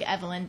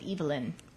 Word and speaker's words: Evelyn: But there's also a man's name, it looks like Evelyn, but you pronounce it Evelyn.